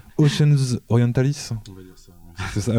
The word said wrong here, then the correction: Oceanus Orientalis: Alors, comment tu le prononces, toi? – Je sais pas. – N'oublie pas ton micro, par contre. – C'est Oceanus Orientalis, je crois. – Ah, Oceanus, d'accord, j'avais cru que c'était un V.